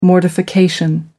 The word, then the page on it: mortification: American English